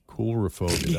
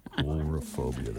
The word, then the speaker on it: coulrophobia: How do I pronounce that again? Chaurophobia. – Coulrophobia. – That was my first instinct. Coulrophobia.